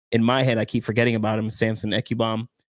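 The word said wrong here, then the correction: Ebukam: I think I say it right. Ibukam. That's how you say it.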